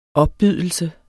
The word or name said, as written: opbydelse